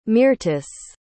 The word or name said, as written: Myrtis